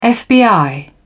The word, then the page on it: fbi: British English